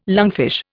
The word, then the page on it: lungfish: Indian